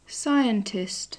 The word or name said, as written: scientist